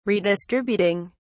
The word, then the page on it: redistributing: British English